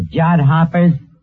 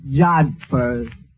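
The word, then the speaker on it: jodhpurs: Absolutely beautiful, Eddie, in her riding pants and her jod hoppers. Yeah, it was love at first sight. – That word is pronounced jodhpers.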